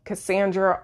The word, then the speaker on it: Cassandra: And my Instagram handle is my name, Cassandra Austin McDonald.